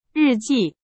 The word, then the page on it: 日记: Chinese